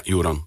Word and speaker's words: Joran: Die werd dus gemaakt door Joran Moerkens. – Zeg ik het goed? Joran? – Ja, Joran.